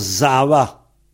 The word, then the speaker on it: Sava: Mais ça s'écrit Sava. Ça écrit S-A-V-A. Sava. Mais on prononce Zava.